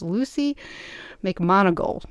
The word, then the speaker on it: Luci McMonagle: And so I'm really excited to be talking to my guest, and her name is Lucy McMonagle.